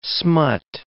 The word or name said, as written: smut